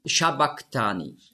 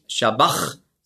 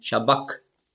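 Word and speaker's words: sabachthani: Und das CH im Griechischen, das ist ja ein Buchstabe, das Chi, das hat man ausgesprochen so wie ein C hinten im Gaumen. Also nicht Shabbach, sondern Shabbak. Und das repräsentiert das Hebräisch, das aramäische Q.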